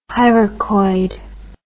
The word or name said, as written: hyracoid